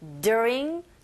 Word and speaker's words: during: During 전치사잖아요. 그죠? 뭐뭐 동안. 그래서 얘도 사실은 강하지 않습니다. 그런데 발음할 때 during 첫 번째 이렇게 힘 조금 주시고 during the breakaway from the rain 했어요.